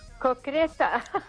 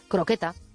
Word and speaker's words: croqueta: Se acepta cocreta porque como hay gente que lo pronuncia muy mal, pues entonces han decidido que vale igual. pero bueno, la palabra correcta es croqueta.